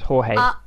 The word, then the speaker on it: Jorge: George Gutierrez? – I think it's Jorge. – Jorge.